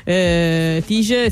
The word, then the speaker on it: Tice: Tice, si scrive T-I-C-E. – Brava, hai fatto bene a dirlo. – Perché se no poi magari lo cerchi con la G, non la trovi.